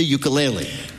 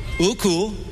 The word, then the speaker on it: ukulele: the ukulele. – Peter's first question to Taimane: how the hell do you pronounce ukulele? – Ukulele. – Uku. – Lele. – Lele. – You got it.